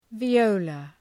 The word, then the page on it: viola: vi:’əʋlə